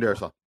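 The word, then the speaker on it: Darrisaw: Is how am I pronouncing that right? Is it Darisaw? – Yep. Christian Darisaw.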